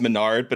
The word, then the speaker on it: Mesnard: I'm in the same boat because my last name is Menard, but it's spelled Mesnard, and uh.